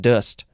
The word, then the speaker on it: dost: Next is, I guess you would say dust? Maybe it's dost? The pronunciation guide is making it look like it's just dust. It's spelled D-O-S-T. The vowel sound is just like an sound. It's that upside down E. I think it's called the schwa, maybe?